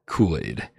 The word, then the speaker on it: Culade: His name is spelled C U L A D E, and so I said Kool Aid because I'm dumb, and he says it's pronounced Kool Aid.